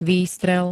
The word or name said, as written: výstrel